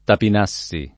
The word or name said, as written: Tapinassi